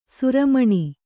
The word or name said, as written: ಶ್ರಮಣ